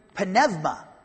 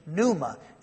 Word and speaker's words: pneuma: It's another prepositional phrase here, preposition and translated by, and then the word dynamis, translated powers, power, and then the word Penevma, or as we, in the Erasmus pronunciation, pneuma.